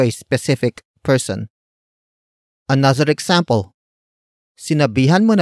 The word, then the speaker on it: sinabihan: Word for word it's sinabihan mo na ba siya.